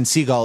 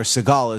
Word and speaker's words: Segel: Because I was listening to an interview with Jason Segel, or Segal as the British say,